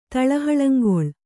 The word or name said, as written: ತಳಹಳಂಗೊಳ್